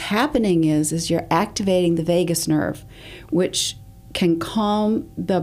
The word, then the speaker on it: vagus: Vagus is how it's spelled, V-A-G-U-S.